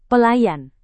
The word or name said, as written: pelayan